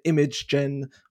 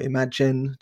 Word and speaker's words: Imagen: Their ImageGen, or Imagen, depending on how you want to pronounce it, model produces, I think they had, you know, a panda sitting in front of a painting of flowers and things like that.